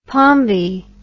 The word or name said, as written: pombe